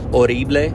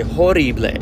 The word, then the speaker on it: horrible: Okay, a little bit of a roll on the R, and the H is silent. And when I used to say it, I used to say horrible, like with the H, a strong H.